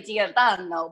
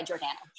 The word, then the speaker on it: Giordano: If you were Italian, say Giordano, but we just go by Giordano.